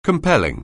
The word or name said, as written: compel